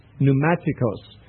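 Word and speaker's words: pneumatikos (πνευματικός): This word in the Greek is pneumatikos, and it's spelled with a P at the beginning, which if in English would be silent. In Greek they'd pronounce it P-N-E-U-M-A, pneuma. That's the Greek word for spirit. And then the suffix I, let me get this right. No, excuse me. T-I-K-O-S, pneumatikos, means spiritual.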